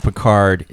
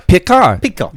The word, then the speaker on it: Picard: Picard. They wouldn't pronounce the D.